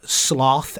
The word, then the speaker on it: sloth: Or how Americans rhyme sloth, S-L-O-T-H, with cloth instead mm-hmm. of sloth, like the British do. And she points out the British pronunciation even shows the word's ancient relation to the word slow.